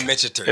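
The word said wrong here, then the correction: Michter's: Gotta learn how to say it. The Michters. – The best thing that guy when we went on the tour, the guy said it's like Victor's. Yeah. With an M, I was like, yo, all right, I'll never forget it.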